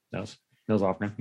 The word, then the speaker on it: Nils Lofgren: Is it Nils or Niles Lofgren? – Nils Lofgren, yeah.